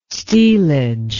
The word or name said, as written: stealage